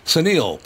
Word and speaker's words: Suneel: Suneel, pronounce sun like the hot ball of fire in the sky and eel like those ugly water snakes.